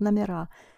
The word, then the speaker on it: номера: Номера, because the stress shifts to the ending or becomes unstressed, so you pronounce номера.